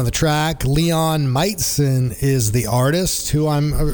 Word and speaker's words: Majcen: And digging what I'm hearing, Meitzen, his last name, a little bit tricky. So if you're looking for it, wherever you get your music, M-A-J-C-E-N. But from what I understand, it's pronounced Meitzen.